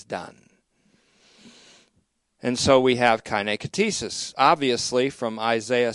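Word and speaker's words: kaine katesis (καινὴ κτίσις): Sixth, there's the kaine katesis, K-A-I-N-E. You'll notice I'm just using transliteration now into the English uncials. But it's kaine katesis, K-A-I-N, long E.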